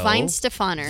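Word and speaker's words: Weihenstephaner: Weinstephaner. – Weinstephaner. It's spelled super weird, but Germans don't pronounce a W.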